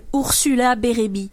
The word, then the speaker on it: Ursula Bérébi: Je m'appelle Ursula Bérébi. – Oh, mais là, c'est trop bon! – Ursula Bérébi. Bérébi, est-ce que ça s'écrit comme bi, comme une abeille? – Ouais.